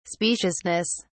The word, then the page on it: speciousness: British English